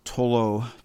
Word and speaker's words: Thollot: And this is a group led by a guy named François Tolo, T-H-O-L-L-O-T, French, if you couldn't tell from my pronunciation.